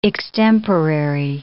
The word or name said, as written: extemporary